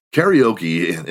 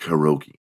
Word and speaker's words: karaoke: Karaoke in America is how we say it, but it's actually pronounced karaoke.